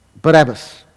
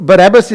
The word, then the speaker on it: Barabbas: So Barabbas is the Greek pronunciation of his name, but the Hebrew pronunciation of his name would be Bar-Abbas,